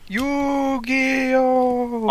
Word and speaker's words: Yu-Gi-Oh!: Did I pronounce that right? – Yu-Gi-Oh! – Oh, Yu-Gi-Oh! Okay, Yu-Gi-Oh! Okay, cool. You gave us an 8 out of 10, Duelist, on PS4.